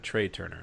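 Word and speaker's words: Trea Turner: Is there a Trey Turner or is it a Trey Turner? I don't even know who Trey Turner is. – It's spelled like T, but with an R. Trey Turner.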